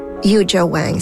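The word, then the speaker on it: Yuja Wang: Yu Jo Wang, c'est comme ça qu'on prononce son nom.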